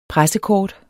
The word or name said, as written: PRESSEKORT